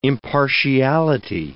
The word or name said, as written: impartiality